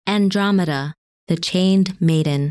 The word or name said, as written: Andromeda